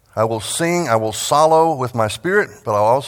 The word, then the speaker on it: psallo: That word sing is the Greek word solo. We might spell that P-S-A-L-L-O or L-L-O-W, solo, the P is silent.